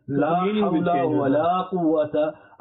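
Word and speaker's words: La Hawla Wala Quwwata (لا حول ولا قوة): You have to read it correctly. La Hawla Wala Quwwata. And Qaf, this Qaf is the two dots, is pronounced back of the mouth. So Qaf, not Kaf, not Quwwata.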